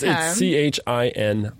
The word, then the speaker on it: Chinon: Should we keep hearing Chignon? – Chignon, no. – Almost spelled the same. – Yeah, it's Chignon. Chignon.